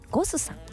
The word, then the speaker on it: GOSS: ゴスさん、 GOSS と書いてね、ゴスさん、そしてお寿司屋さんが 2店舗、お魚のブリにもんと書きましてシモンというお店が銀座に 1 店舗、日本橋に 1店舗ございます。